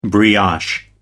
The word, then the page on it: brioche: American English